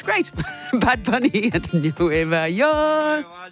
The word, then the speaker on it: Nueva York: And I've been learning the cities, and it's Nueva York is how you actually pronounce it, according to Duolingo. Anyway, then I discovered that that's the way Puerto Ricans pronounce New York, as in Nueva Yol and not Nueva York, like other Spanish-speaking countries.